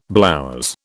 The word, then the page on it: blouse: British English